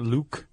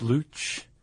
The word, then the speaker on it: luchador: That's my luchador name. – Okay. And you pronounce it correctly? It's not Luke. It's Luch.